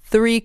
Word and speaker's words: three: Three cars. We have three cars. – تلفظ سه یه خود دقت و تمرین میخواد. باید نک زبونی بگید و سریع بچسبونید به ر.